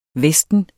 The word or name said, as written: vesten